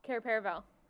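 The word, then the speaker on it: Cair Paravel: Care Paravel? – Care Paravel.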